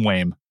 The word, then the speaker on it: WAME: So I guess the next thing is, how are you pronouncing the acronym WAME? – WAME. Okay. Yeah, we're going WAME.